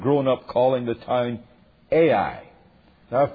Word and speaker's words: Ai: And however you have grown up pronouncing this big city with two letters, you will have to bear the Cambridge University way of doing it, and that makes me sound very learned, as we will pronounce it Ai, and I know a few others can pronounce it that way.